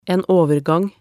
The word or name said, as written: en overgang